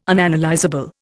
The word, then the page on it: Unanalysable: Indian